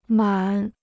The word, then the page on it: 满: mǎn